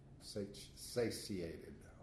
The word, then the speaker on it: satiated: so the eyes of man are never satiated. I can't even say that. Sated. Is that how you pronounce it? S-A-T-I-A-T-E-D? Sated? – Satiated. – Satiated.